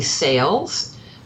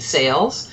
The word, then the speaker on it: Sayles: My name is Nancy Sayles, uh, and I'm a book publicist. Um, I'm just lucky enough to actually have the name Sayles.